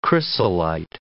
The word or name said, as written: chrysolite